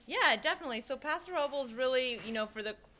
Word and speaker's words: Paso Robles: It's Paso Robles, yeah.